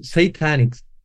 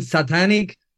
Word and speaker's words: Satanic: I just realized like 2 days ago in another interview that it's pronounced satanic. Satanic. I've been saying satanic forever, and you haven't corrected me.